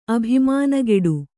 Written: ಅಭಿಮಾನಗೇಡು

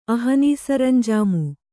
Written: ಅಹನೀಸರಂಜಾಮು

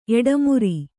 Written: ಎಟ್ಟಿಮರ

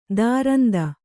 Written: ತುರಂತ

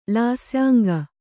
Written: ಲಾಸ್ಯಾಂಗ

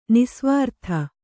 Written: ನಿಃಸ್ವಾರ್ಥ

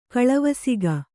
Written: ಕಳವಸಿಗ

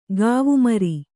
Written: ಕೂವೆಮರ

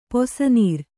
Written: ಪಿಸನಾರಿ

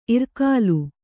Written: ಇರ್ಕೆಲ

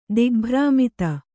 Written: ದಿಗ್ಭ್ರಾಮಿತ

